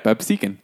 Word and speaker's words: Absecon: According to a release Saturday, the Absecon, I said that right? – Yep, Absecon.